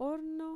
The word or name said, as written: orno